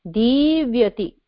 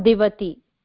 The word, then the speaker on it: divyati: Divyati. Div has a short E. And is not divati. But it is divyati. There is a ya also in the middle.